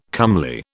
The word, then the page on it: comely: British English